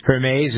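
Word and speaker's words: Hermès: And so he's going to give the house, the Hermes fortune. Hermes, is that how you pronounce it? I don't know how to. – Hermes. Mm-hmm.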